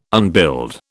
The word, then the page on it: unbuild: British English